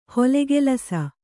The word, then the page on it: ಹೊಲೆಗೆಲಸ: kannada; holegelasa